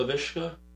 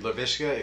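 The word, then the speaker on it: Laviska: So, I was one of those people that took LaVisca. Is it LaVisca or LaVishka? – LaVisca. – LaVisca, okay. Yeah.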